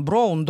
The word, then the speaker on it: brown: То есть он приходил домой, там говорил, читал домашнее задание, там, brown, down, я говорю, brown, down, да, спокойно, это читается как ау, такое вот.